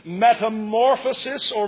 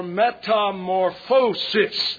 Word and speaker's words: metamorphosis: metamorphosis or metamorphosis. There it is. Both pronunciations are correct. Not the tongue-tied one I gave you, but the two proper ones.